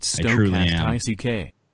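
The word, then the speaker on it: stochastic: It's pronounced Stokast I-C-K. – Stokast ick? – Stokastic. Bye, Scotty.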